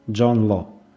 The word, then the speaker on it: Джон Ло: поэтому правильнее будет произносить его фамилию так, как она произносилась во Франции – Джон Ло.